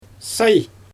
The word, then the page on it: saj: Xyzyl